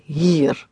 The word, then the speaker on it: hier: Make a nice long hier. One more time. – Het is niet hier.